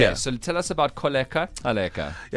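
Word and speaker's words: Kholeka Gcaleka: Say her name very slowly one more time for the people who can't click with their mouths. – Advocate? – No, we can say that, Thabo. – Koleka. – Koleka. – Kaleka. – So it's Koleka Kaleka. – I hope I'm saying it right. – It's like a little rhyme. Holly, please just fact check this man. – That is correct.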